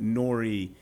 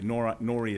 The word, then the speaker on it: Nori: It's just so much easier to say Norrie because they can't mess up the spelling, N-O-R-I. But Norrie is the correct pronunciation.